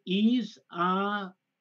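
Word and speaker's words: Isaias: The pronunciation, the Atlantic Basin storm name pronunciation for this storm is Isaiah. Wait a minute. Isaiah. It puts the stress on the double E. Isaiah. Oh, why did they do that? Isaiah. – E-E-S.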